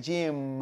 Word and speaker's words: Jim: Jim con M. Jim. – Jim. – No es lo mismo que diga Jim con N. – Ok. – Y cerrar la boca con M. Jim.